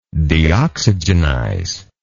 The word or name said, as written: deoxygenize